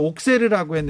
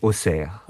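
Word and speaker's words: Auxerre: 그 우리나라에서는 옥세르라고 했는데 발음은 옥세. 어떻게 합니까? – 어, 우리 지역 발음으로 네. 아지 오세요. – 오세. – 근데 프랑스 사람들은 다른 지역에서는 아지 옥세요. – 그러니까 X가 옥세. – 우리 우리 지역에서는 S로 발음하고 다른 지역에서는 에, e, 그 X 발음으로 해요.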